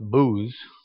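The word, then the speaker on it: Buz: Buzz, properly pronounced Booz, was the son of Nahor, Abraham's brother.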